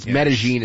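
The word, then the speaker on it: Medellín: Why do you say Medellin? – That's actually how you say it. I know in Entourage they say Medellin, but it's actually Medellin. They say Medellin. – Medellin, yes. Medellin is how it's actually pronounced.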